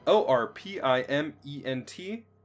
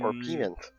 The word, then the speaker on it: orpiment: O R P I M E N T and I wouldn't know how to pronounce that. Um, Orpiment.